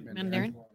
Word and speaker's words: Mandarin: now that we do have the real Mandarin. Did I say that right? – Mandarin.